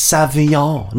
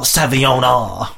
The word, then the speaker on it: Sauvignon: You don't pronounce the N properly. It's like the French don't have time to pronounce letters. So it's like oh ho ho ho ho ho. Sauvignon. – Here. – It's Sauvignon, not Sauvignon. It's Leviosa, not Leviosa.